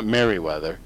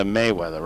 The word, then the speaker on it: Mayweather: It's not Merriweather, like Malcolm Merriweather. But it's Mayweather, right?